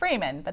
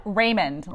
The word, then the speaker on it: Riemond: Hey, I've got some good information for you today from Natalie Riemond. Did I say that right? – It's Raymond, but that's okay. – It's what? – Raymond, like the Raymond.